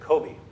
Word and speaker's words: Kobe: But they didn't pronounce it like the city, Kobe, they pronounced it Kobe.